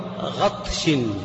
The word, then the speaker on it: غطش: غطش كذا انطق جيدا، غطش وبطش. أيوة. – على غطش وايش؟ – وبغش. – وبغش على غطش، الغطش الغين والطاء والشين، هذا هو الغطش، اي على ظلمة.